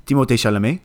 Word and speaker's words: Timothée Chalamet: It's Steve Carell, and since I'm doing the French pronunciation of everybody's name, Timothée Chalamet.